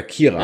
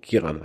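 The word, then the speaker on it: Ciarán: Und dann sehe ich so Cyrian Hines. Ich so, ah, cool. Oder Kieran, ja. Ist ja, glaube ich, jetzt ausgesprochen. Kieran Hines.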